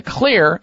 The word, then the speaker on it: Xlear: Now the Xlear, and that's spelled with an X, X-L-E-A-R, the X stands for xylitol, which is one of the great ingredients in the Xlear nasal sprays.